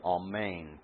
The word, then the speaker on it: amen: Also, I think you know what's happening here, but also that I pronounce this word amen twice. This is the way it would be pronounced in both Hebrew and in Greek.